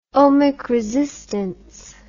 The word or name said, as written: ohmic resistance